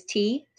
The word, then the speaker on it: Thi: Oh, I will add that my name is actually like a double name because my Viet name, mm-hmm. my parents say is T. So T-H-I mm-hmm. is how it's spelled in Vietnamese.